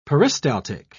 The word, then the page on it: peristaltic: British English